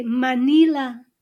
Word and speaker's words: Manila: Say Manila. – Manila. – Manila. Manila. Do you remember, do you see the pattern where the stress is? Second to the last syllable.